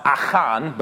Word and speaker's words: Achan: Achan, or as it should be pronounced, Achan, but I will not do that anymore.